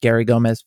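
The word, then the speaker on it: Garey Gomez: It's just Gary Gomez Photo.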